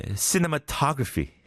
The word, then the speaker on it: cinematography: 또 힘든 게 cinematography. Cinematography. 오에 있는 거예요.